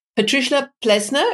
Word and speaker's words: Patricia Plesner: Patricia Plesner, is this the way you pronounce it? – Exactly.